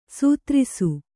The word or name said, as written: ಸೈತಿರಿಸು